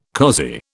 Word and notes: cozy: British English

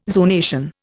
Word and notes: zonation: Indian

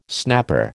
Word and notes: snapper: American English

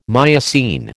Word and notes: Miocene: American English

